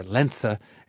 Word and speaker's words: Lenze: When I was a teenager, my mom had a part-time job working for Lenze Corporation, or Lenze, if you want to do the German pronunciation, a German-based company that distributed proprietary machine parts.